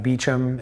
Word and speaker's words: Beauchamp: It's Adam Beauchamp. It's spelled B-E-A-U-C-H-A-M-P.